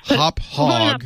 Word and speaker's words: Hauppauge: It's pronounced Hop Hog.